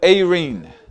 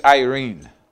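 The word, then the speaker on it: eirene (εἰρήνη): The word peace on the Greek is the word eirene, Or you guys would say Irene in English. It's spelled E-I-R-E-N-E.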